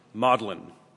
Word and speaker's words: Magdalene: We actually get the word maudlin from Magdalene. I think it's how the British pronounce the word Magdalene, because Mary weeps, she's maudlin.